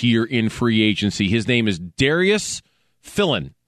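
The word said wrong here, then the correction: Philon: Or Filan, I'm sorry, Filan. I've said that wrong.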